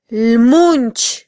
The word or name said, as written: lmunch